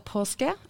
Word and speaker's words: Paasche: Hi, I'm Lisa Posca.